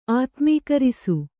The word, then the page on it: ಆತ್ಮೀಕರಿಸು: ātmīkarisu